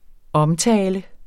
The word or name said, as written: omtale